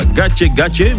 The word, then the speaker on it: Gake Gake: Oui, ça se prononce comme ça, Gache Gache, mais ça s'écrit Gake Gake.